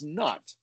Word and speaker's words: Nut: Also Nuit, there's another spelling and pronunciation which really fits the cat, and that's Nut, because she's a nutter.